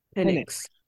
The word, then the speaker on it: Penix: Penix.